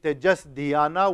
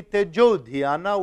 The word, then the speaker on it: Tejo Dhyana: It, instead of saying Tejas Dhyana, which brings one extra syllable and breaks the rhythm, you say Tejo Dhyana, which sounds much more fluid to the tongue.